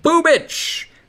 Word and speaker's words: Bubich: Making his major league debut, Chris Bubich. B-U-B-I-C. It is pronounced Bubich.